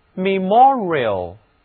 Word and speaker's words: memorial: 好，我们给 m-e-m-o-r 这个词根加上另外一个形容词后缀 i-a-l 组成的词读作 memorial。 memorial 在这里字母 e 改读作短的元音 e，而字母 o 读圆口的长元音 o。